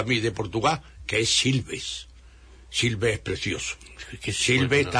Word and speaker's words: Silves: Silves, tal como se pronuncia, con V, Silves.